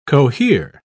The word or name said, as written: cohere